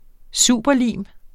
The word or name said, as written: superlim